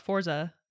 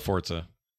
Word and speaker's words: Forza: It's Forza. That's how you say it. – You say it like with a T. – Yeah, you're supposed to. – Is it not Forza? – It's like Italian or something.